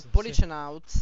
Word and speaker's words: Policenauts: Prima questione, come si pronuncia Policenauts? – Policenauts. – Policenauts. – Io penso Policenauts. È tipo, Psychonauts, sì, però con la police davanti. Quindi Policenauts. – Esattamente.